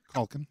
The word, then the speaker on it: Culkin: Culkin. Yeah. – There's no L in there? – I mean, if there is, it's silent.